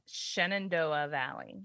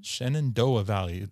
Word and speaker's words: Shenandoah Valley: Shenandoah Valley. – Shenandoah Valley.